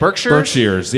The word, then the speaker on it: Berkshires: How do you say it? – Berkshires, yes.